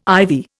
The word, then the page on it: ivy: Indian